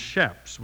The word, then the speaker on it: chaps: Shaps is spelled C-H-A-P-S, and it's pronounced shaps with an S sound.